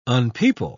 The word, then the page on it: unpeople: British English